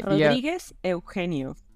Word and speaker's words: Rodriguez Eugenio: Rodriguez Eugenio.